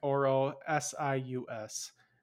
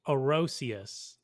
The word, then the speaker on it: Orosius: It is O-R-O, like Oro, S-I-U-S. That's as much as I can pronounce it Orosius.